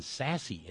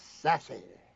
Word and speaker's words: Sasse: There are people who call themselves a new kind of Republican, like Ben Sasse in Nebraska, boys and girls, but he's not really. He's still just the same old right-winger. – Bob, you said it wrong. It's Sasse.